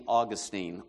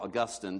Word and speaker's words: Augustine: You might recognize it, Saint Augustine, or Augustine, depending on how you pronounce it.